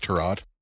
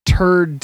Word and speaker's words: Tyrod: If Tyrod Taylor beats the Jets, I think it's Tyrod. – If Turd Taylor, I'm going to mispronounce it more every time you correct me. – Tyrod. – Turd. Three R's.